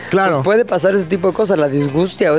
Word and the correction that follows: disgeusia: o La, ya ves que hasta la, pronunciación está disgeusia.